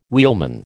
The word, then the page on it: wheelman: American English